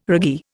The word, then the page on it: raggee: Indian